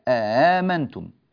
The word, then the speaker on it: آمنتم: ونحذف هذه الألف ولا ننطقها حتى لا يجتمع عندنا هذه الألف مع هذه الألف بهذه الطريقة، همزة ثم ألف ثم همزة ثم ألف، لا هذا لا صح، فتصير آمنتم.